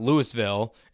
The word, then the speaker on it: Louisville: It's not Louisville. It's Louisville, Kentucky, where he's from.